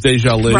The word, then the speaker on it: Deja Lee: I behave all week long, and I'm not going down over Deja Lee, which is spelled like Deja Vu, but it's Deja Lee.